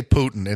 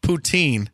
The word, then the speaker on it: poutine: And as far as, well, I don't want to say it wrong. I want to say Putin, and that's the president of Russia, and that's not right. Poutine.